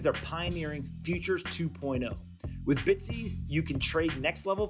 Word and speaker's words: BTSE: It's spelled B T S E. That's right, Bitsy.